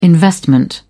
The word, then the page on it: investment: British English